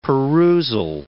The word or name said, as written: perusal